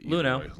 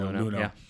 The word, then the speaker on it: Luhnow: I forget how you pronounce it. – Luhnow. I've always gone with Luhnow.